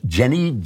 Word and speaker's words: Jenae: I'm not going to answer it here, but I just want to mention it's from a lady named, I believe it's pronounced Jenny, J-E-N-A-E, but I'm going to call her Jen because I don't want to keep mispronouncing her name.